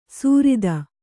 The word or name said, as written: ಸುರಿತ